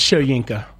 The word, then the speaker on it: Shoyinka: It's Shoyinka. – Yeah, there it is. – Hey, the clear thing is we don't know who this guy is, really. Yeah. – Joe Tryon Shoyinka.